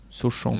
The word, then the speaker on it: Sochan: moi je le trouve par exemple moins, j'aime moins son attitude que celle de Jérémy Soran. Sochan, mhm. pour ceux qui veulent prononcer à la française.